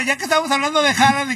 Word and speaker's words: Haaland: Realmente, discúlpame, es que realmente tienes que pronunciarlo mejor, es Haaland.